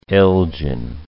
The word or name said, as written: Elgin